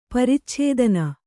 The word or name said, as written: ಪರಿಚ್ಛೇದನ